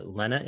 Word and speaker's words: Lena: You have a long chapter about the Lena image or Lena image. How am I pronouncing it? – Both are acceptable.